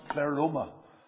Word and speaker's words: pleroma: And the one that I want to deal with today is pronounced pleroma.